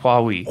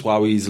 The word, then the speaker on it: Huawei: Huawei.